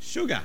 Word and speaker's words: sugar: There's only one word that is spelt with S and pronounced sh. And she said, it's sugar.